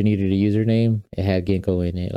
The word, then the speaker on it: Ginko: It's pronounced Ginko like the herb, G-I-N-K-O, Ginko.